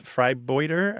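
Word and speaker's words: vrijbuiter: And it's pronounced freibouter, I believe.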